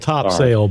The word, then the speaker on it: Topsail: It's spelled Topsail, but if you're from around here, it's Topsail.